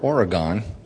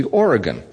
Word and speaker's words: Oregon: I called it the way I always grew up in New York, Oregon, but it's supposed to be Oregon.